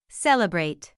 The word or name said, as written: celebrate